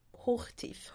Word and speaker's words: Hochtief: Och huvudadministratör för det här företaget var tyska byggfirman Hochitef. Hochtief. Hoch, ja tack.